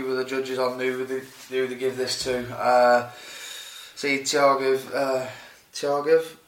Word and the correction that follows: Tukhugov: saying his name right? – Tukhugov. – Jesus Christ. Tukhugov, yeah.